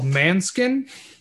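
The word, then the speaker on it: Måneskin: And if we go to Waffle House, and already both cooks are sitting outside, blaring a band called Manskin. Not Manson, like Manson, it's spelled all weird.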